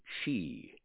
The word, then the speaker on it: Xi: The president of China pronounces his name Xi.